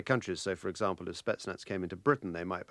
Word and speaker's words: Spetsnaz: Yes, Spetsnaz.